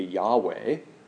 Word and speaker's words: Yahweh: Our culture has known him as the name Jehovah, although we now think that a better pronunciation in Hebrew would be Yahweh.